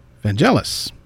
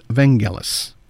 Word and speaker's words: Vangelis: It never came through, but at the same time, he told me that the name is pronounced with the hard G, so it is Vangelis.